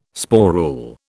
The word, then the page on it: sporule: British English